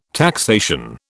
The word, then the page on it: taxation: British English